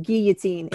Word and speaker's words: guillotine: It is pronounced guillotine. It is French.